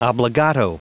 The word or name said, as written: obbligato